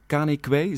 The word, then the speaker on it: Kane Kwei: Ehm, een, kunstenaar uit Ghana, Kane Kwei, zeg ik het goed? – Ja.